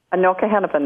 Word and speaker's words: Anoka Hennepin: Anoka Hennepin, that's correct.